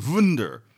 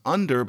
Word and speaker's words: wunder: And so under, in earlier written English, is often wunder. It's pronounced under, but the idea is that you use the V shape at the beginning.